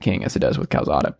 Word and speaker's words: Calzada: Calzada. – Calzada, I guess.